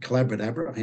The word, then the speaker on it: Collaboradabra: It rhymes with abracadabra, hence magic. Collaboradabra, hence collaboration.